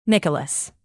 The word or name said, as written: nicholas